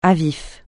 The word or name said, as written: à vif